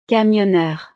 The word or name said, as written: cammioneur